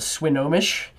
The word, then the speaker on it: Swinomish: It's the, I'm gonna butcher this name, the Swinomish Indian tribal community. So tribal community. Did I say that wrong? – Oh yeah, that's right.